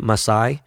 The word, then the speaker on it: Masai: They're trying to get Masai, is how you pronounce his name, for the GM for the Raptors, the president of the Raptors.